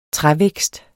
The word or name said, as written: trævækst